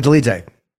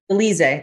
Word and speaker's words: Dolidze: Roman Dolidze. – Dolidze. That's how you pronounce it.